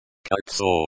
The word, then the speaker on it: Кольцо: Золото is pronounced like the o in hot, the еру in кольцо. – Кольцо is a short I sound.